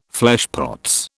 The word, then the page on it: fleshpots: British English